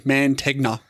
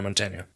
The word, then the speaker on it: Mantegna: Say it phonetically. Mantegna. It's Montaigne.